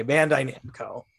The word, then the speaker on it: Bandai Namco: Well, you had it. It's just your cadence was very funny. – Okay, Bandai Namco.